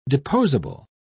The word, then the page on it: deposable: British English